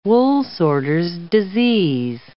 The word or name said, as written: woolsorter's disease